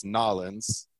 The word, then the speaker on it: New Orleans: So you are from, I'm going to say multiple ways, New Orleans, which is not how you say it. New Orleans, yeah. Which is how it's okay to say it, but really it's Nolens, right? – There you go.